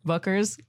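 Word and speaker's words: Bueckers: And then UConn, you got Paige Buckers. Is that how you say her name? – Yeah. – Buckers? – Yeah? – Yeah. Okay.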